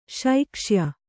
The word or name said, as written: ಶೈಕ್ಷ್ಯ